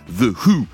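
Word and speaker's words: The Hu: The Who, ça s'écrit H-U.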